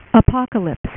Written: apocalypse

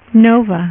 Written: nova

